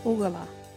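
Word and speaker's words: Ugarla: Ugala.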